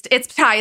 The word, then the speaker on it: Tighe: It's Tighe. It's spelled T-I-G-H-E, but it's pronounced Kevin Tighe.